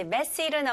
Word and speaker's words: messy: 근데 중요한 거는 강세입니다. 여기서는 첫 음절에 강세를 주시면 돼요. 그래서 messy가 아니고 messy. messy.